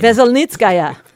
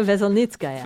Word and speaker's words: Veselnitskaya: Veselnitskaya. – Thank you. – Natalia Veselnitskaya.